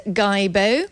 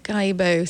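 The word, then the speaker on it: Guillebeau: written by Chris Guibo. That's how I'm pronouncing it anyway. It's G-U-I-L-L-E-B-E-A-U. So it's French, isn't it? Guibo, something like that. Guibo, the best-selling author of The $100 Startup.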